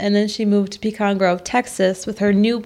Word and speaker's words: Pecan: Well, how do you say it? Pecan or Pecan? – Like Pecan. The nut.